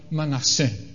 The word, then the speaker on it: Manasseh: Joseph named his firstborn Manasseh. Is that how you say it in English? Yes, good.